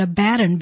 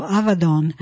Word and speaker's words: Abaddon: We often pronounce that Abaddon, but it's actually pronounced a V, that V, and it is Don ending, so Avadon.